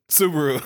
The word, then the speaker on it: Subaru: How do you say that truck name? – Subaru? – Subaru.